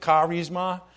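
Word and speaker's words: charisma (χάρισμα): It's charisma. That's how you pronounce it, charisma, and it means a gift of grace.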